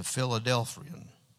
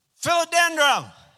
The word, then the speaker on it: Philodendron: It's a Philadelphian. Did I say it right? Philadelphian. What is that plant? Philodendron.